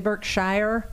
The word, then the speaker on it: Berkshire: We're sitting here and looking at some beautiful, are these the Berkshire, are these mountains? – Yeah, these are mountains, Berkshire mountains. – You say Berkshire and I say Berkshire. – Like, yeah, Berkshire's the local pronunciation.